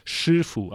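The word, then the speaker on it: Shifu (师父): Und wenn man es auch richtig aussprechen würde auf Chinesisch, dann würde man sagen Shifu, aber das kann ich jetzt nur sagen, weil ich äh, Chinesisch auch sprechen kann.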